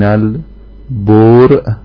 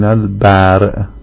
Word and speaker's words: البرء: در ادامه بحثی که داشتیم رسیدیم به این عبارت: و هل یشترط فی وجوب الاستنابه الیأس من البرء یا الیأس من البرء؟ هر دو تا تلفظ شده این متن.